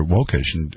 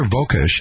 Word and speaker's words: Wokush: Her name is spelled W-O-K-U-S-H, and I called her Vokush, or something about it. It's Heather Vokush, and she called me, recorded early in the week, from Vienna, Austria.